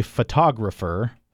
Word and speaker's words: photographer: Okay, so the next one that you said and that I have here in front of me, photographer. Photographer. So that's a four-syllable word, and now I'm stressing the second syllable there.